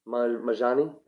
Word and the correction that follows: Manji: Manji. Manji. Yeah. Jumanji. – You put an extra syllable in his last name, I just thought.